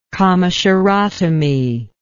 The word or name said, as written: commissurotomy